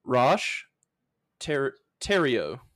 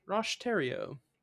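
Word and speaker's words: Roch Thériault: But the Ant Hill Kids cult was under the leadership of Rosh Theriault, which is spelled like Thoreau or Theriault, and Rosh is spelled like R-O-C-H. So it's a little confusing. But it's like T-H-E with an accent, R-I-A-U-L-T.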